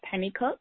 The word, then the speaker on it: Pennycook: And my email is Makeda, and that's M as in Mary, A-K-E, D as in David, A, Makeda, at Makeda Pennycook. And my last name is spelled exactly as it sounds. Penny, P-E-N-N-Y, C-O-O-K with an E at the end.